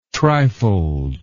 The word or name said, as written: trifold